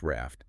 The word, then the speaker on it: RAFT: And we just pronounce that like the word RAFT, right? – Exactly, just RAFT.